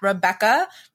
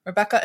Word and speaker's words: Rebekah: So I'm at Rebekah. Rebekah is spelled a little differently.